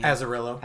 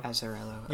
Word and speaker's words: Azzarello: After reading Joker, I decided to check out a few more titles by Brian Azzarello. I'm probably butchering the name. – Azzarello. Azzarello.